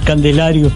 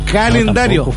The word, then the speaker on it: calendario: Es que me cuesta pronunciar bien las palabras y me cuesta decir candelario. ¿Candelario? – Calendario.